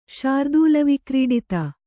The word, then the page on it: ಶಾರ್ದೂಲವಿಕ್ರೀಡಿತ: śardūla vikrīḍita